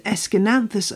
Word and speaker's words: Aeschynanthus: It's spelt A-E-S-C-H-Y-N-A-N-T-H-U-S. How on earth? Well, just break it down. It's S-K-N-A-N-T-H-U-S, with the emphasis on the nan.